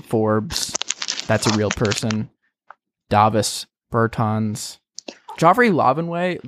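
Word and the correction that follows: Lauvergne: how do you spell it? – Laverne. – See, it doesn't look like Laverne.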